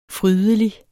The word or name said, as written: frydelig